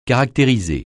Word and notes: caractériser: francais

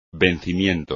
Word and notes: vencimiento: espagnol

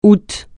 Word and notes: août: francais

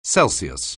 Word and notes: Celsius: anglais

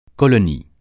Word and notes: colonie: francais

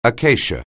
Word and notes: acacia: anglais

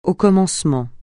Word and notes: au commencement: francais

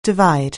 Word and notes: divide: anglais

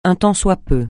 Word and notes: un tant soit peu: francais

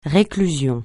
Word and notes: réclusion: francais

